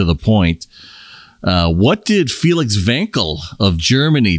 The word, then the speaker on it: Wankel: The Wankel rotary engine. – Great guess. – With authority. – With authority. – I like your pronunciation. – Thank you. Thank you very much. – W pronounced as a V.